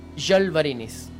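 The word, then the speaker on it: Žalvarinis: Y ahí supe que se llamaba Jalvarinis. Porque la Z se ah. pronuncia como Y, pero cuando tiene el acento es con la boca como ah, patilo, así.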